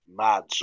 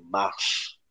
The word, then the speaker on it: Mads: But yeah, Mads, or Mass, as you really ought to pronounce it, it's remiss of me not to.